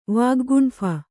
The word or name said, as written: ವಾಗ್ಗುಂಫ